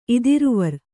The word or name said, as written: ಇದಿರುವರ್